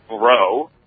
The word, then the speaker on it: Breault: It's my mother's maiden name, and it's Bro, B-R-E-A-U-L-T, which doesn't look like it's spelled Bro at all,